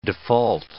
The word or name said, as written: default